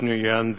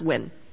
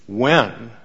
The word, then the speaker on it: Nguyen: Judge Nguyen. The first thing is... – It's actually pronounced Nguyen. – Nguyen. Okay.